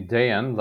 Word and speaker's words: Dejan: It's actually Dejan, like yogurt, but Dejan.